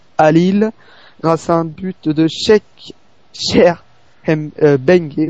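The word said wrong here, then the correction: M'Bengue: Voilà, oui, parce que le M se prononce bien. Oui, je galère. Cheikh Mbengue, le grand match.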